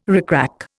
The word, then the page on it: rickrack: Indian